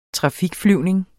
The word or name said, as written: trafikflyvning